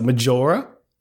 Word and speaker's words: Majora: Majora. Like, how else would you pronounce that? Majora? – Exactly.